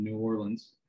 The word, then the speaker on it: New Orleans: In today's episode, we'll discuss the Just for Today meditation with the sponsorship family and down here in New Orleans.